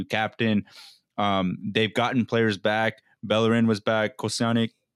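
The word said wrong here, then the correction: Kolašinac: I can't still say his name. Yeah, Kolasinac.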